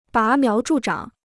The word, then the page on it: 拔苗助长: bá miáo zhù zhǎng